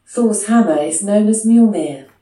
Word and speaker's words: Mjolnir: That's like, it's Mjolnir. – What? – Jolinir? – The M is silent.